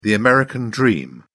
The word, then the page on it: american dream: British English